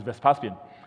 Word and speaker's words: Vespasian: The way to pronounce it is Vespasian.